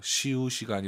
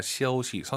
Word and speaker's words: 休息: 쉬우시가 아니라 쉬어우시.